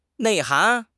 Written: 内涵

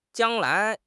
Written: jiang1 lai2

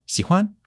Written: xi3 huan5

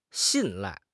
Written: 信赖

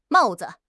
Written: mao4 zi5

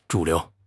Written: 主流

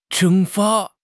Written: 蒸发

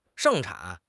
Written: 盛产

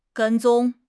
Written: 跟踪

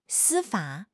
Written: 司法

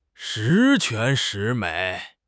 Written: shi2 quan2 shi2 mei3

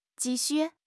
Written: ji4 xu4

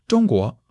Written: Zhong1 guo2

